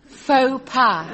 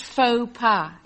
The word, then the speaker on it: faux pas: what's this say? Fox pas? – Faux pas, Mike. It's pronounced faux pas. It's French.